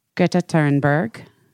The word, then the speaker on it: Thunberg: Uh, I am truly like cannot get over the Andrew Tate being arrested because of Greta Thunberg. That's how you say it. That's how you say it. That's the correct way to say it.